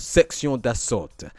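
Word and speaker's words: Sexion d'Assaut: Let me make sure I'm saying it right, actually, because how embarrassing it would be with my French teacher would be very, very but Section d'Assault, if you remember that hip-hop group from France.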